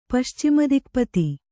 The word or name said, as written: ಪಶ್ಚಿಮದಿಕ್ಪತಿ